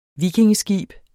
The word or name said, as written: vikingeskib